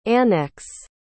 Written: annex

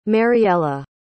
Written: Mariella

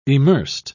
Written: emersed

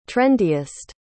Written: trendiest

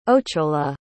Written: ochola

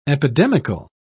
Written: epidemical

